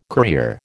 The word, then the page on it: courier: American English